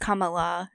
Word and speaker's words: Kamala: It's pronounced Kamala. It rhymes with Pamela.